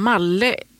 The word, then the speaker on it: Malle: Jag tror det uttalas som Malle. Stavar man namnet i alla fall. Fredrik Malle kan vi säga då.